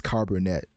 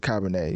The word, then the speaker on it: Cabernet: That's not how you pronounce it at all. I was told you pronounce it Cabernet. You know, I was on a date, so I guess that makes up for it. But I was I was told it's pronounced Cabernet, and um, let's just say that joke definitely landed.